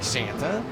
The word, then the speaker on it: Santa: Santa, his last name is spelled S-A-N-T-A. Looks like Santa, but it is pronounced Santa.